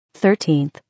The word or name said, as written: 13th